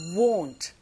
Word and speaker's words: won't: 특히 W-O-N-T, 요게 이제 원하다라는 동사와 비슷하게 들린다고요? 아니요, 다릅니다. 입을 동그랗게 한 상태에서 won't.